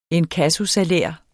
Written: inkassosalær

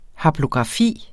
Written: haplografi

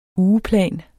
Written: ugeplan

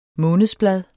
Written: månedsblad

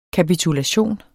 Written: kapitulation

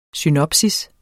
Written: synopsis

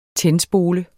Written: tændspole

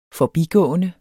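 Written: forbigående

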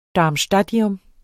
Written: darmstadtium

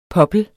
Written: poppel

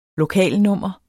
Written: LOKALNUMMER

